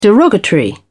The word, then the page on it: derogatory: British English